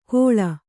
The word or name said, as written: ಘೋಳ